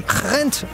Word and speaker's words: Gent: hvordan det kommer til at slutte mellem Rent og AGF hos dig, Tobias Hansen. – Ja, en flot flamsk udtale, du har der.